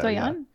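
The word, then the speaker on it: Doillon: Did I say her name right? I think that's how you say it, right? – So, Doyon? Yeah.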